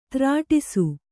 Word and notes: ತ್ರಾಡಿಸು: Kannada

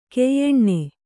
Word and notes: ಗಾಯನಿ: gāyani

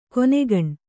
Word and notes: ಕಣ್ಣುಗಾಣು: kannada

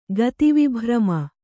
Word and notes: ಗತಿವಿಭ್ರಮ: kannada; gati vibhrama

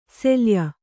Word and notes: ಸೆಲ್ಯ: selya

